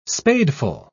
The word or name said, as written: spadeful